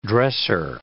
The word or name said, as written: dresser